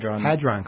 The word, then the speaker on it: Hadron: Okay, we basically have all heard about the large, how do you say it, Hadron Collider? – Hadron Collider, yeah.